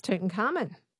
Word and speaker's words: Tutankhamun: Tutankhamun. – Yes, it's Tutankhamun.